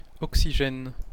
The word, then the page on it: oxygène: /ɔk.si.ʒɛn/; [ɔk.si.ʒɛn]